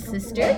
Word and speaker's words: Sisster: So again, my stage name is Sister, and it's spelled a little different. It's S-I-S-S-T-E-R, but the easiest way for you to be able to find my music is on Spotify under Sister Music.